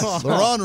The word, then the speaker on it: Laurent: Laurent Robinson's coming back? – I was thinking about him. – And he's pronouncing the T now. – It's Laurent is his name.